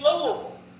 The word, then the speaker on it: Louisville: They say Louisville. Okay. Is like bull, all right? Same as Knoxville. It's not Knoxville, it's Knoxville. Every V-I-L-L-E, a Southerner should be saying it like it's bull, V-U-L.